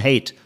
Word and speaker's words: Haidt: Jonathan Haidt oder Haidt, H-A-I-D-T, mhm. äh, ein amerikanischer Sozialpsychologe, der an der New York University lehrt, Jonathan Haidt, mhm. so spricht man ihn aus, Jonathan Haidt obwohl es ja zu den guten Traditionen unseres Podcasts gehört, dass zumindest Lars und ich Heide.